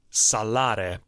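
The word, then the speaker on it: psallere: Okay, so Bruhns highlights with melismas the words that describe singing and music making, um, like cantare and salare. Psar, yeah, they don't pronounce the P, but uh, psalm, like to praise.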